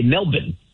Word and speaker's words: Melbourne: But in Australia, they say Melbourne. Because you don't really pronounce the R's.